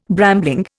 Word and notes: brambling: Indian

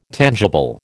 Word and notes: tangible: American English

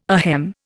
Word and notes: ahem: Indian

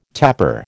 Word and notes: tapper: American English

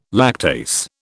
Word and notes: lactase: British English

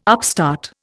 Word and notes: upstart: Indian

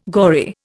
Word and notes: gory: Indian

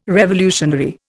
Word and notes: revolutionary: Indian

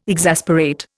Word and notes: exasperate: Indian

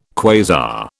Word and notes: quasar: British English

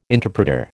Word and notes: interpreter: American English

